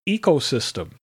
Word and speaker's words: ecosystem: And ecosystem. Ecosystem starts with a long E, not a short E. A long O, a short I, and a short E sound.